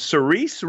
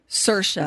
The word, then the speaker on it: Saoirse: Is it Cerise Ronan? – Saoirse. – Is that how you say? – Saoirse. Saoirse Ronan. Boy, all right, we'll go with Saoirse then.